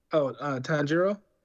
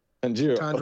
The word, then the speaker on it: Tanjiro: T-A-N-J-I-R-O. – Tanjiro? – Tanjiro, okay. So yeah. I was like, that's how you say it? I'm like, that does sound more like authentic Japanese. I don't know why I've been saying it like a Texan. I'm like, Tanjiro.